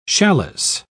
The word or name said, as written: challis